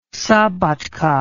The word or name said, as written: szabadka